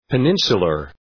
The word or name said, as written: peninsular